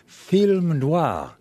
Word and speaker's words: noir: and at that institution teaches a course in film noir.